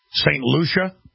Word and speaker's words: St. Lucia: So we have more countries issuing travel bans on Ebola-stricken nations, like Colombia and different Caribbean islands like St. Luke. Is that how you pronounce that? St. Lucia.